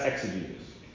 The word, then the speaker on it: exegesis: Exegesis is, if you want to spell that, E-X-E-G-E-S-I-S.